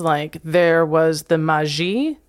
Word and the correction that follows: Majlis: Am I pronouncing Majlis. – That correct? Majlis.